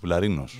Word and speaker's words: Βουλαρίνος: Βουλαρίνος. – Βουλαρίνος, στο ρι τονίζεται.